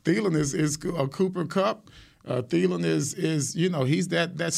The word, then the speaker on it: Thielen: But the problem you have is Thielen. – Right. – Did I say his name right? – Yeah. – Yes. – Thielen.